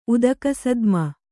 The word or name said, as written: ಉದಕಸದ್ಮ